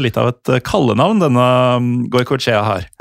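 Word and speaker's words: Goikoetxea: Jeg tror det uttales Goikoetska. – Ja, til dere lyttere, det skriver, altså etternavnet han skrives G-O-I-K-O-E og så T-X-E-A.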